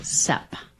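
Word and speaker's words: cèpes: Bob did look up seeps, and it's pronounced sep.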